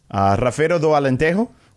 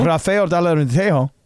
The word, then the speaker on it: Rafeiro do Alentejo: Rafeiro do Alentejo. – There you go. – Rafeiro do Alentejo.